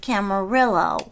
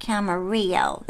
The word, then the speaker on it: Camarillo: Randall said it's actually Portuguese settlement or something, and the way you pronounce it is Camarillo, not Camarillo. That would be Spanish.